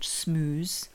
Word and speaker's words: smeuse: But it's not spelled how we might think it's spelled, possibly. – It's not got double O in it. – No, it's not got double O. So it's, how is it spelled? – S-M-E-U-S-E. – Okay.